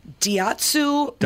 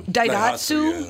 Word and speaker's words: Daihatsu: Daihatsu. – Daihatsu yeah.